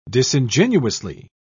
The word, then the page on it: disingenuously: British English